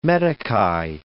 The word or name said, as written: meticais